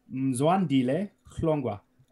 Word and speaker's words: Mzwandile Hlongwa: He beat Mamzile, let me say it properly, Mzwandile Hlongwa, and he beat him by a Das choke.